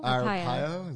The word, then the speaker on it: Arpaio: Arpaio? Is that how you say his name? He got away with it? – Yeah. Okay. Mm-hmm.